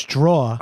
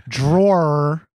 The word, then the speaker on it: drawer: It's draw. But that's not how you say it either. Drawer.